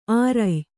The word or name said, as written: ಆರಯ್ಯು